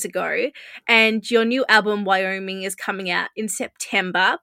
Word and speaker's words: Wyoming: So you have released, prior to your album Wyoming, did I say that right? I'm an Australian and can't pronounce states very well. – That was perfect. Wyoming.